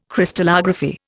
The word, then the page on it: Crystallography: Indian